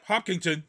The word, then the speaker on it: Hopkinton: And is it pronounced Hopkinton? Is that how you pronounce it? – Yep. Hopkinton. Yep.